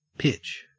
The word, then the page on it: pitch: Australian English